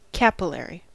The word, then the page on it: capillary: American English